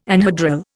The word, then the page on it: anhedral: Indian